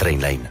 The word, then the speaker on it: Trainline: Es pronuncia Trainline. Descarrega't l'app de Trainline i estalvia comparant milers de trens i tarifes.